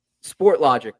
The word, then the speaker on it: Sportlogiq: Logic with a Q. I love that. – Yeah, it gets mispronounced a lot, so good on you for getting it right. We're in Montreal, so like people will throw the French kind of twang to it or Sport Logic IQ.